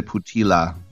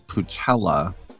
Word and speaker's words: Putila: San Francisco Giants spelled Putila, but tell everybody, pronounce it like patella tendon. So don't say Putila. It's Putella, he says.